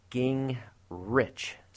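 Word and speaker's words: Gingerich: Well, I decided maybe I need to start doing Gingrich so that the world begins to get it right, because I get a lot of mispronunciations of my last name.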